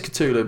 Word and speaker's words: Cthulhu: Some sort of Cthulhu yeah. – thing. And I think it's actually pronounced Cthul, but...